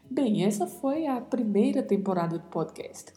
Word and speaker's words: temporada: Temporada é um substantivo que tem o mesmo significado no português, com sentido de época, de período, e a diferença de pronúncia é muito suave, né? – Temporada. Apenas o O mais fechado, o E mais fechado e a letra A mais aberta. Então pronunciamos temporada.